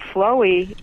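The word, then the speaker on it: Phloe: It's spelled P-H-L-O-E. P-H-L-O-E. Flowey, I think that's pronunciation. – Yeah, some people pronounce it flow, and I'm not sure.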